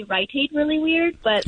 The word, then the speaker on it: Rite Aid: No, Rite Aid. Emphasis on the right.